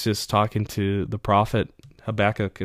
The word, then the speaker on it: Habakkuk: So in Hab- Hab Habakkuk, oh my gosh, Habakkuk, that's how you say it in the Old Testament.